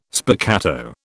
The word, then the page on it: spiccato: British English